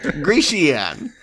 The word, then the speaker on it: Grecian: Grecian. I think you were adding an extra syllable. Grecian.